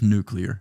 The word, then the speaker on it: nuclear: It's just nuclear. Nuclear. N-U-C-L-E-A-R. Nuclear. – Yeah. – There's no extra U in there. We didn't buy that fucking vowel.